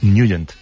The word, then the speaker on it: Nugent: Pero la pronunciación correcta es Nugent. – Te fuiste a Washington y descubriste que era Nugent.